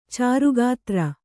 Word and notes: ಚಾರುಗಾತ್ರ: cāru gātra

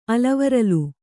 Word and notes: ಆಲಿವರಲ್: kannada

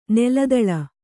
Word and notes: ನೆಲದಾಳೆ: neladāḷe

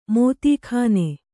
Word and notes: ಮೋದೀಖಾನೆ: mōdī khāne

